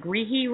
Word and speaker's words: Grihi: And now, did I pronounce the Grihi right? – You sure did. That's his name. I spelled him that way because it's the easy way to know how to pronounce it. – Exactly. Exactly. When I said it, I'm like, if I'm messing this up, I'm just dumb, because how can I mess this up? It's really clearly Grihi, but I just wanted to make sure.